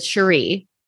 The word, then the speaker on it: Cherie: We've been saying her name wrong because it's spelled the same way as Sheree, but Cherie.